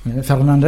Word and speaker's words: Fernandes: Fernandes, hein, c'est peut-être un peu comme ça qu'il faut prononcer, en portugais.